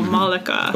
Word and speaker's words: Malaka: She does say it in her book, saying that it's not difficult, it's Monica with an L, so Malika.